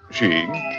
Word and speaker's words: skiing: Skiing, pronounced sheeing, is a sport that appeals especially to the rugged, wide awake, out of doors individual.